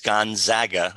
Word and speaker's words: Gonzaga: And he'll let us know whether he likes Gonzaga, that's the correct pronunciation,